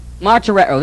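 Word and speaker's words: Martyreo (μαρτυρέω): Martyreo, this is the verb form. M-A-R-T-E-R-O. That's a long O.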